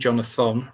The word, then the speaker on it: Jonathon: Jonathon rather than Jonathan.